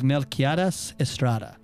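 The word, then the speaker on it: Melquiades Estrada: Three Burials of Melquieras Estrada.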